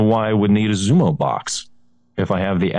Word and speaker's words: Xumo: But new customers, and I'm not sure how you pronounce this, X-U-M-O, Xumo? – Xumo. Yeah, it's a Xumo box.